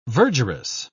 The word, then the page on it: verdurous: British English